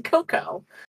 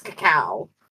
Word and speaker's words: cacao: I swear to you, anytime I read cacao, I read as cacao. It's not cocoa. Cocoa spelled C-O-C-O. That's no. True. No, that's true. It's cacao and cocoa.